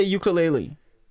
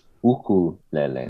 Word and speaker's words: ukulele: How do you properly say ukulele? – It's pronounced ukulele. – That's what I thought. Ukulele. – Ukulele. Yeah, ukulele. – That makes more sense. Ukulele. – Phonetically, yes. – Yeah. – Ukulele. So you have to make sure you say ukulele, because if you say uku in our language, you're talking about all the lice in your hair.